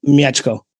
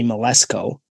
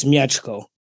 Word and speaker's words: Mieczko: If you're doing it in English, you would say Malesko. – Yeah, that's what your name is to me. – But in Polish, it's Mieczko.